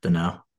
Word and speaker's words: Danault: You don't pronounce the L and the T. – Danault.